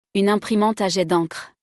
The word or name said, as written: Une imprimante a jet dencre